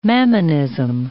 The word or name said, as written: mammonism